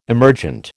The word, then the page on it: emergent: American English